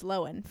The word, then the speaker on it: Lohan: Just give us a speaking of Lindsay Lohan, actually pronounced Lohan, fun fact.